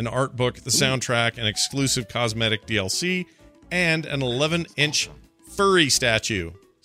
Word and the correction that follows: Fury: So that's not furry. Fury. – Furry. I was going to say Furry, I was like, what has that got to do with anything? – I said it wrong. Fury statue.